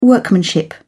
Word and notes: workmanship: British English

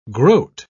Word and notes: groat: British English

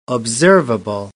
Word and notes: observable: British English

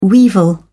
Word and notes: weevil: British English

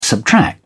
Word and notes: subtract: British English

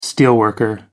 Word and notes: steelworker: American English

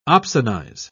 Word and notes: opsonize: British English

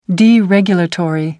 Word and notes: deregulatory: American English

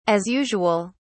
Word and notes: as usual: British English

